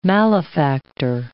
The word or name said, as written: malefactor